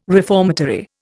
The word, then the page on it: reformatory: Indian